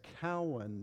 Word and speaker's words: Keown: Now, don't even try to figure that out if you've seen the spelling, because it's not spelled like C-O-W-A-N or anything like that. It's K-E-O-W-N. Looks like Keown, but I was raised being told it was Cowan, so I'm sticking with that.